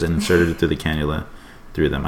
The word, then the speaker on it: cannula: Going back to factual errors, Dylan's nasal cannula, is that how you pronounce it? – Yeah.